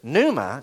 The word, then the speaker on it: pneuma: Pneuma starts with a P. The P is silent.